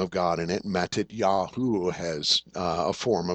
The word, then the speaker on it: Matityahu: In Hebrew, it's pronounced Matityahu. Matityahu.